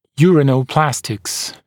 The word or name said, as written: uranoplastics